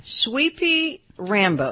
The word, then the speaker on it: Sweepee: Sweepy Rambo. And it's spelled S-W-E-E-P-E-E.